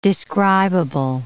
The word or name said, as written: describable